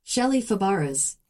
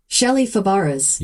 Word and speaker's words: Shelley Fabares: Shelley Fabares. – Okay, did you get that one more time? – Shelley Fabares.